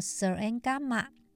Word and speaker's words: Śūraṅgama: 都称为《楞严经》，它的梵语呢，念作 Sarangamma，它是大乘佛教的经典。